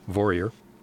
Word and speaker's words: Vorreyer: So her last name is spelled V-O-R-R-E-Y-E-R, pronounced Vorier.